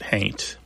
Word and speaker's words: haint: Somebody might spell it haunt, but pronounce it haint.